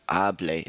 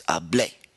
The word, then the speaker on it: hablé: It's really important we have that accent on the end. So it's not hablé, it's hablé.